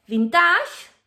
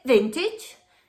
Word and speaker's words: vintage: Palabras que acaban con -age No se dice vintage, se dice vintage. No se dice village, se dice village. No se dice message, se dice message.